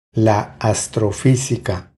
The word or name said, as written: La astrofisica